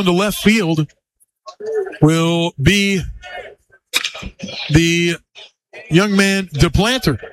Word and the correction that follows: DePlantier: As actually, DePlantier is the proper pronunciation.